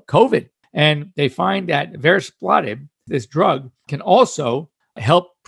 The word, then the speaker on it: Varespladib: It's called Varis. Platib, V-A-R-E-S-P-L-A-D-I-B, Varis Platib, and it neutralizes venom in snakes.